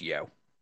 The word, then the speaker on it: Yeoh: I don't think I pronounced that right. – I think it is yo.